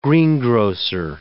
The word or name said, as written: greengrocer